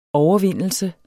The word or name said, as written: overvindelse